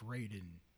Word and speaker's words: Raiden: Yep, but that's how we pronounced it in the game. It was pronounced Raiden.